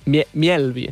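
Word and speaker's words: Mjällby: A veure com el pronuncies. – Mielvi, no? – Mielvi. – Mielvi, perquè porta Digresi al damunt de la... – Exacte. – És el Mielvi, que és... Des... Miel... – Bé, ja està perfecte.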